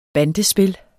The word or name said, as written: bandespil